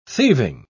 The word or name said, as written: thieving